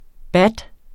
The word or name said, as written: baht